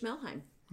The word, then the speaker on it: Melheim: And it is by Rich Melheim. – Okay. Can you spell that last name? It's a funky one. – M-E-L-H-E-I-M.